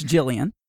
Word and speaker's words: Gillian: It's Gillian.